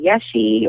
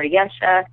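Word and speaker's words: Yeshe: And, you know, a little thing about my name, sometimes people aren't sure how to say it. They don't know if they should call me Yeshi or Yesha. And I just want to say, I think it's all okay, because if you say Yeshi, you're basically saying yes, she, mm-hmm.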